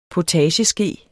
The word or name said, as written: potageske